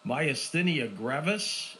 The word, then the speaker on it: myasthenia gravis: He says, my dad is 82, Doc, has severe, I'll see if I can pronounce this right, myasthenia gravis. Any ideas of what he can do to minimize it? Did I pronounce that right? – You did perfect. You get an A+.